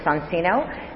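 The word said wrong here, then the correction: Soncino: and then I gave a lecture a few weeks ago in Tel Aviv, and a young Italian man walked over to me and said, "Very nice lecture, but you kept saying Sansino. It is Sancino." So I'll continue saying Sansino, but just with the awareness that the correct pronunciation is Sancino.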